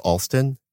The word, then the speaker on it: Alston: I'm Bryan Alston, and my name is spelled B-R-Y-A-N. And my last name is Alston, A-L-S-T as in train, O-N.